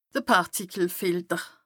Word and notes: de Pàrtikelfilter: Bas Rhin

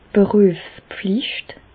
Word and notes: d' Berüefspflicht: Bas-Rhin; Reichshoffen

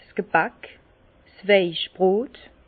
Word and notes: 's Gebäck, 's Weichbrot: Alsatian; Bas-Rhin; Herrlisheim

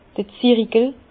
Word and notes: d'r Zirikel: Bas-Rhin; Herrlisheim

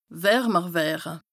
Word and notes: wärmer wëre: Bas-Rhin; Strasbourg

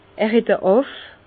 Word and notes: er het e Àff: Bas-Rhin; Herrlisheim

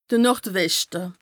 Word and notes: de Nord-Weschte: Bas-Rhin; Strasbourg

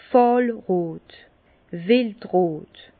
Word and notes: fàhlrot, wildrot: Bas-Rhin; Herrlisheim

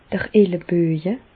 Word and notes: d'r Elleböje: Bas Rhin; Reichshoffen

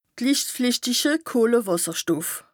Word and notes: lichtflìchtiche Kohlewàsserstoff: Bas Rhin

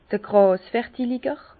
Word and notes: d'r Gràsvertilger: Bas-Rhin; Herrlisheim

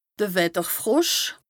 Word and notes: de Wetterfrosch: Bas-Rhin; Strasbourg

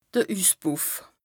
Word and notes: de Üsspùff: Bas Rhin

